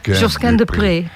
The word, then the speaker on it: Josquin des Prez: Zo spreek je die naam dus uit. – Josquin des Prez. De Prez.